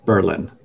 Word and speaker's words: Berlin: It's spelt like Berlin, as that city in Germany, but we pronounced it Berlin.